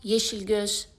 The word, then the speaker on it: Yeşilgöz: En zij schrijft: mag ik een fonetische weergave geven van de naam van minister Yeşilgöz? Die wordt vaak verkeerd uitgesproken. Misschien helpt dit. Yeşilgöz. De G wordt dus als de G in het Franse garçon uitgesproken.